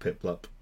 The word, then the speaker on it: Piplup: And Pilpup, however you pronounce that, Piplup. – Penguin. It's like Piplup.